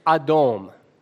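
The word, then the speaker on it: Adom (אדום): The word for red, friends, in Hebrew is pronounced Adom.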